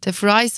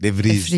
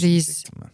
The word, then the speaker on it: de Vries: DeFries falan mı düşündün? – DeFries yok. DeVries. DeVries.